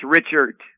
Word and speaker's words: Richart: It's Richart. – Okay. – You pronounce the R-I-C-H and then the ERT, E-R-T.